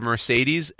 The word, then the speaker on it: Mercedes: The word Mercedes, M-E-R-S-E-D-E-S, each E in that word is pronounced a different way. – But isn't Mercedes M-E-R-C-E? – M-E-R-C-E. – You just said S. – Okay, M-E-R-C-E-D-E-S.